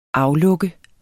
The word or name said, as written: aflukke